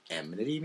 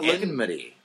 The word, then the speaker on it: enmity: Do you kids know what enmity means? – Yeah, it's. I hope they do. And it's not enmity. – It's enmity. – E-N. – It is E-N. – E-N-M.